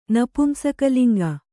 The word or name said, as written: ನಪುಂಸಕಲಿಂಗ